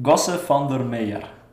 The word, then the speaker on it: Gosse Van Der Meer: Gosse Van Der Meer.